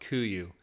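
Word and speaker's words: Kuiu: Unless you're an experienced sheep stalker, you've probably never heard of Kuyu, named after an Alaskan island pronounced Kuyu.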